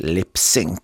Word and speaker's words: Lips Inc.: Och här kallades ju den här gruppen felaktigt för Lips Incorporated. Men i efterhand har de varit väldigt tydliga med att det ska uttalas lipsync. En slags ordlek för att läppsynka. Alltså att mima till musik.